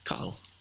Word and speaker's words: Cathal: C-A-T-H... A-L. – Cahill. – Oh, is it? – Yeah, that's how you pronounce it.